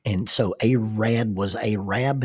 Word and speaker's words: Arab: It is pronounced Arab, not Arab. Arab, Alabama. It was supposed to be Arad, Alabama, A-R-A-D, and the postmaster flopped that letter over.